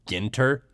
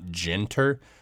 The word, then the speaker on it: Ginter: You know, I decided to do gin this time and would also like to use this opportunity to tell you guys my last name is pronounced Ginter, not Ginter. I'm a human with a Dutch background, not, you know, a bottle of liquor distilled from juniper berries.